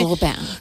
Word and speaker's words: Lupin: Lupin, o Lupin, no sé exactamente cómo se dice. – Lupin.